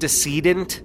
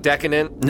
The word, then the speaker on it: decedent: Is it decedent? – Yeah. – I think so, yeah. – Alright, it's not decadent? – No, it's not decadent.